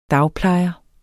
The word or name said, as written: dagplejer